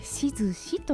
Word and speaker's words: 質志: はい、はい、こちらしずし鍾乳洞すみません漢字の説明をしますと、はい、質問の質それから「志す」という字で「しずし」と読むそうです。